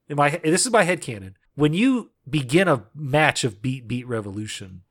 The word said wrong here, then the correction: BB Revelation: I don't want you to mispronounce it for the rest of the podcast. – It's BB Revelation.